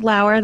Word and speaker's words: Lauer: Your last name, is it pronounced Lauer? That's how I always have it in my head. – Lauer. Mm-hmm. Yep. Lauer Power.